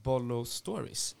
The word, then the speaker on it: Bollo Stories: Och, och, nu har ni även dragit igång Bollo Stories. Eh, kan, uttalet kan vara fel. – Ja, nej, men det är helt rätt.